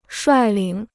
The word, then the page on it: 率领: shuài lǐng